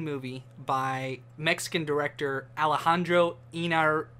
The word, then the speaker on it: Iñárritu: Iñárritu. – Iñárritu. – I-N-Y-A-R-I-T-U.